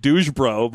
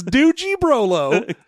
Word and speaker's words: Doogie Brolo: I was going to say it's probably pronounced like Douge Bro, but... – No, it is Doogie Brolo.